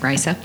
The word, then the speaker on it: Risa: Well, this is the one where we have Riker on Risa. That's how you say it, Risa.